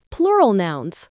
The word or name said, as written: plural nouns